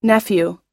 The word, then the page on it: nephew: long-u